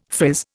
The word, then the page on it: frizz: Indian